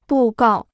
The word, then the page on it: 布告: bù gào